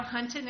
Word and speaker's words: Hunton: Gail Hunton, H-U-N-T-O-N.